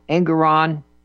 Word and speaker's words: Engoron: Well, Engoron sort of rhymes with moron.